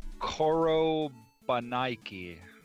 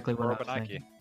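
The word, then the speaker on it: Korobeiniki: It's, it is Korobanaiki. It's the... – Korobanaiki. – Yeah. and I actually... Fun fact, I pronounced that perfectly.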